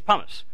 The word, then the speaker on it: pumice: Is that how you say it correctly? Okay. It's pumice.